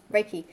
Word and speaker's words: Reiki: So it's in the Reiki. Is that how you pronounce it? – Reiki.